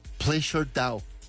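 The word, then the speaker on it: PleasrDAO: Es como unos, pues como unas iniciales, se pronuncia Pleasure Dao, en una operación valorada en varios millones de dólares.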